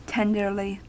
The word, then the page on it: tenderly: American English